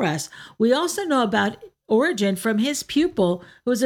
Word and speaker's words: Origen: So Origen, his name is spelled O-R-I-G-E-N, was born in Alexandria, and he was born to a very devout Christian family.